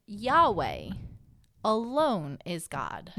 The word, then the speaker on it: Yahweh: Yahweh is how we would say it. – How we pronounce it.